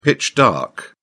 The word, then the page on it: pitch-dark: British English